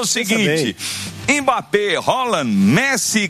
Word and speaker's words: Haaland: Ele falou: a pronúncia do meu nome é Haaland. Haaland, Messi, Cristiano Ronaldo.